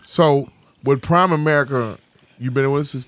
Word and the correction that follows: Primerica: So, let me first correct you in the pronunciation of it. – Okay, so just Primerica.